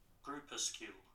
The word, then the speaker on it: groupuscule: I still can't say it. – Groupuscule. – Groupuscule. Let's just let... – Groupuscule. – That's apparently how we should pronounce it.